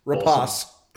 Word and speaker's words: Rapace: Is that how you say it? – Yes, I looked it up on YouTube. Rapace.